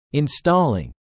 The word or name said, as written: installing